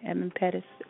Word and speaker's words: Edmund Pettus: Everyone in this country, black, white, you know, whatever your nationality is, whatever your political party is, everyone has an Edmund Pettus in front of them right now. I think I'm saying the name right, Edmund Pettus. – Yep.